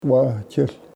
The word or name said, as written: wahchehl